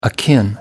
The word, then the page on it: akin: American English